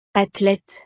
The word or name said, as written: athlete